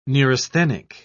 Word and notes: neurasthenic: British English

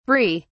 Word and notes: bree: British English

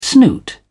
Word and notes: snoot: British English

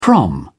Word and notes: prom: British English

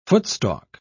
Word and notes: footstalk: British English

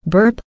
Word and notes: burp: American English

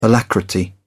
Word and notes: alacrity: British English

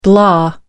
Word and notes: blah: American English

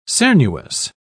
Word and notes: cernuous: British English

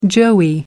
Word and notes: joey: American English

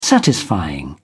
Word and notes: satisfying: British English

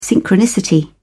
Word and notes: synchronicity: British English

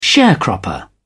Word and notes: sharecropper: British English

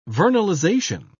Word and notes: vernalization: British English

